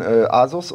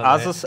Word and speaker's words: Asus: Asus heißt ja nicht Asus, ne? – Alles gut. – Asus heißt ja eigentlich Asus. – Asus! – Das ist die offizielle Aussprache.